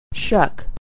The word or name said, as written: shuck